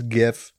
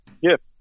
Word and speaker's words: GIF: And yeah, he said it's GIF with a soft G. – GIF.